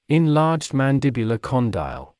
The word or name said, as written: enlarged mandibular condyle